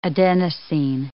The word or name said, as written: adenosine